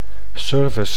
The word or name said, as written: service